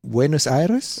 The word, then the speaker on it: Buenos Aires: Du hast das so schön ausgesprochen, okay. auch mit diesem gerollten R am Ende eben, oder? – Buenos Aires? – Oh, schick, schick.